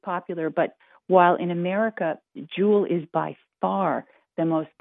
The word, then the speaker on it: Juul: Juul rhymes with cool. Mm. It sounds like something very valuable, as in a J-E-W-E-L.